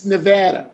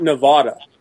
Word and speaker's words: Nevada: It's just not Nevada. – It's not Nevada, okay. – Not Nevada. It's Nevada.